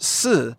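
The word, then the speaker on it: si (四): The number four is pronounced si, which is the same word for the word death, by the way.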